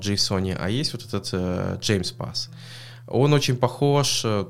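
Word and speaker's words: JMESPath: Произносится оно как James Pass. То есть я бы сказал JMS, но James Pass.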